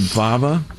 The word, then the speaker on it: Vava: My New Swag from, is it Vava? – Yeah. – Is that how we pronounce the name? – That's right.